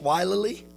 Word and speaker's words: wilily: And when the inhabitants of Gibeon heard what Joshua had done unto Jericho and to Ai, they did work wilily. That's how it's pronounced, wilily.